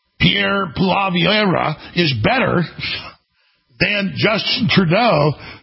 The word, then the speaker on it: Pierre Poilievre: So, Pierre Poilievre, I got that right.